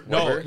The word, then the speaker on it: Weber: It is Weber. Weber? No, it's pronounced Weber.